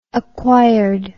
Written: acquired

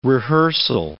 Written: rehearsal

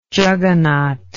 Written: jagannath

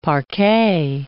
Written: parquet

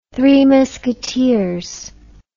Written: three-minute culture

